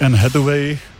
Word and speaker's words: Anne Hathaway: Nevím, jestli to správně vyslovím, Anne Hathaway. – Je to tak, Anne Hathaway, nebo Hathawayová.